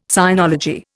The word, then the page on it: sinology: Indian